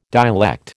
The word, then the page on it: dialect: American English